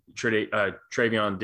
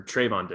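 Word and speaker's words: Trevon: he was one-on-one with Trayvon Diggs like the entire game, or Trayvon Diggs. I'm getting the Trayvon Henderson. Now, anytime I see the name like Trey, I'm like adding an extra syllable in there.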